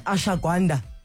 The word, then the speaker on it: ashwagandha: And finally, we're going to have this conversation about ashwagandha.